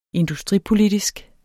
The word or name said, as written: industripolitisk